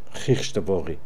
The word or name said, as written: Heechtvori